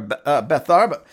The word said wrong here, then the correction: Bethabara: how do I say that? Bethabara, your Bethany, your misery, your place of misery or depression becomes your place of crossing over into something better.